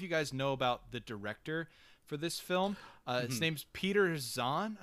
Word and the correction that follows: Sohn: I believe is how you say his last name. – Sohn, I think it was. – Sohn. Okay. Peter Sohn.